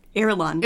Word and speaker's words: Irulan: Irulan. – Irulan? I can spell it. – I-R-U-L-A-N.